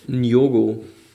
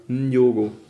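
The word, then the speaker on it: Nyong'o: Die haben da also ein, ähm, ein äh, Ferienhaus und die Mutter, äh, gespielt von Lupita Nyongo, spricht man das so aus? Nyogo. Nyogo? – Spricht man das Nyogo. mit? Ja, Nyogo. Aha, okay. Lupita Nyogo.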